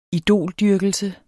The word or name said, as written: idoldyrkelse